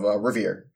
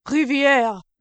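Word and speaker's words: Rivière: mystery nation of Amestris against the nation of Revere, or I think it's Revier. Is that, I'm saying that right? I don't know. – Is it meant to be French? – I don't, it's R-I-V-I-E-R-E. – Riviere. – Riviere.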